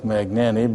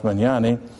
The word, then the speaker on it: Mignani: Looks like Magnani, but it's pronounced Mignani.